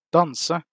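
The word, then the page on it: danse: Norwegian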